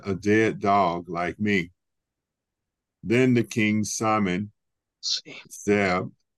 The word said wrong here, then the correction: Ziba: Zeb, Zeb. Is it Ziba? I, I, I said that wrong. Ziba.